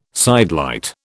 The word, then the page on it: sidelight: British English